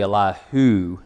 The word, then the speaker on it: Elihu: the son of Eliha. That's actually Elihu, I believe.